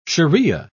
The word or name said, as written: sharia